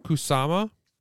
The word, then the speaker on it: Kusama: And I'd also like to say that to the director of this film, which I can never say her name right. – Isn't it Diablo Cody? – No, she wrote it. Karen Kusama. She also directed the pilot episode of Yellow Jackets. – Oh, and I think you did say it right.